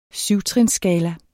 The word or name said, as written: 7-trinsskala